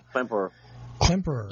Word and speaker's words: Klemperer: Let's see, yeah, Werner Klemperer, is that how you pronounce that? – Klemperer. Yeah, and if you think about it,